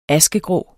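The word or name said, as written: askegrå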